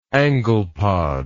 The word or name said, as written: anglepod